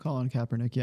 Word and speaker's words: Kaepernick: Kaepernick? – Colin Kaepernick, yeah.